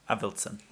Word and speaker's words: Avildsen: Avildsen. – Avildsen.